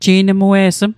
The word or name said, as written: ji'nmue'sm